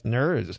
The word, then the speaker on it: Gnirs: Her last name is spelled G-N-I-R-S, Nurs.